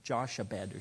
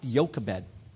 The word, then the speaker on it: Jochebed: It's Jochebed. Now, it looks kind of funny. It looks like Joshabed or something, but it's pronounced Jochebed.